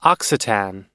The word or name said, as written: occitan